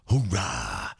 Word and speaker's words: hoorah: But hoorah. – Did I say that right? – A Marine. – Did I say that right? Is it hoorah or hoorah? – Hoorah.